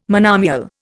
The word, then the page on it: monomial: Indian